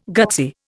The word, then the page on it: gutsy: Indian